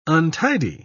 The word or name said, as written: untidy